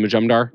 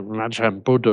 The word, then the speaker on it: Majumdar: You are. That is one of the best pronunciations I've ever heard. – Awesome. – That is fantastic. Because awesome. I get so many, even on the shows, I get, you know, Majum Buddha or all of that.